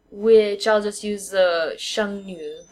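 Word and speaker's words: sheng nu (剩女): So it's sheng, fourth tone, nu, which is like one of those weird ones to pronounce, but it's N with a U and an umlaut over the top, mm-hmm. and the third tone, nu, which means girl.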